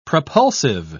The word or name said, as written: propulsive